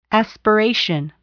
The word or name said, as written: aspiration